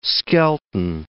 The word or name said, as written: Skelton